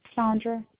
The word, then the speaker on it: Sondra: Sandra. – S-A-N-D-R-A? – S-O-N-D-R-A. – S-O? – Yes. – N-D-R-A, last name is Cunningham? – Yes.